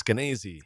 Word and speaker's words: Skenazy: Did I say that right, Skenazy? – I think it's Skenazy. – Skenazy. Okay, Lenore Skenazy.